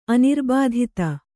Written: ಅನಿರ್ಬಾಧಿತ

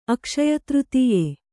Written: ಅಕ್ಷಯತೃತೀಯೆ

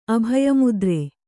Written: ಅಭಯಮುದ್ರೆ

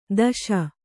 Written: ದೋಷಿ

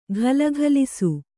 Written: ಘಲಘಲಿಸು

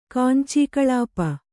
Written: ಕಾಂಚೀಕಳಾಪ

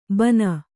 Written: ಬನ

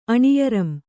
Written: ಅಣಿಯರಂ

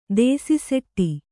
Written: ದೇಸಿಸೆಟ್ಟಿ

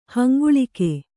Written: ಹಂಗುಳಿಕೆ

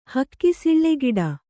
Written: ಹಕ್ಕಿಸಿಳ್ಳೆಗಿಡ

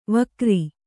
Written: ವಕ್ರೆ

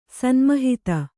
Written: ಸನ್ಮಹಿತ

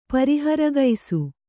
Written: ಪರಿಹರಗೈಸು

